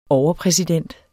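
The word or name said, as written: overpræsident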